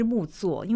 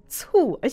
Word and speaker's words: 木酢: 那呃，我读了他的故事哦，但是我想先请他介绍一下到底，因为他是木作达人嘛，到底什么是木作？因为我刚刚在节目开始之前，我还跟他聊，其实我蛮白目的，我都把那个字念成醋，而且我以为那是一种醋，其实这不都不精确哦。